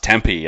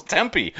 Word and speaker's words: Tempe: That actually might be one of the biggest revelations of this whole thing, is that it's actually pronounced Tempe and not Tempe, like I've been doing for the first 30 years of my life.